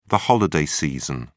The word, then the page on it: holiday season: British English